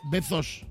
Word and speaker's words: Bezos: No, pero tú lo pronuncias con Z. – Bezos. – Exacto, ese.